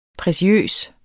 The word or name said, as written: preciøs